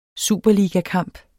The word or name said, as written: superligakamp